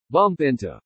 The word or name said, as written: bump into